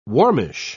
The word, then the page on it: warmish: British English